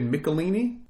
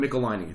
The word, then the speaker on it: Michelinie: Hold on, I always pronounce his name wrong. David Michelinie. David Michelinie. It's David Michelinie and Jim Shooter wrote it, penciled by Sal Buscema.